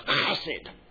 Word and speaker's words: hesed: It is the old Hebrew word hesed, which is close to the minute pronounced something like chesed, and cover everything with spit.